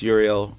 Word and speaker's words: Uriel: My name's Uriel Arguelles.